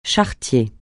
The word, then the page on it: charretier: français